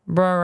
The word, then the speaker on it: Brer: Br'er rabbit. Br'er. Brer rabbit, like brother, but without the O-T-H. – Brer rabbit. Brer fox. – Brer bear. – Brer bear. – Brer frog.